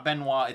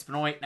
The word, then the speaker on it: Benoit: It's not Benoit, it's Benoit.